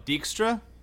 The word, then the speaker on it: Dijkstra: Jixtir, the guy with the king, with the owl, the weird guy that drinks all the time. – Oh, Dijkstra? – Dijkstra, yeah, Dijkstra.